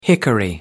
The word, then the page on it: hickory: American English